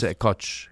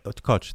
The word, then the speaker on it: Koç: One of the presidents of a Turkish university, the University of Koç, how do you pronounce it? – Yes, in Turkey, it's Koç. – Koç, thank you.